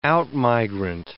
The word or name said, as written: out-migrant